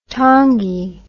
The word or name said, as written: tangi